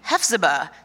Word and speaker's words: Hephzibah: I'm like, Hephzibah, you know? He's like, oh, you mean Hephzibah. So I'm even pronouncing it wrong.